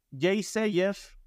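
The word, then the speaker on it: Yeasayer: Están eh, Jay Sayer, que se escribe Yasayer.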